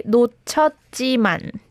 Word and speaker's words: 놓쳤지만: Yeah, it has to be 놓쳤지만. – 놓쳤지만. 지만. – Yes, so the heat in the first syllable is silent.